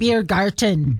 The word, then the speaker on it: Biergarten: Biergarten.